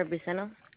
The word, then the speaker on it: Briseno: Elvira Briceno. – Elvira, spell that first name for me. – B-R-I-S-E-N-O. – S-E-N-O.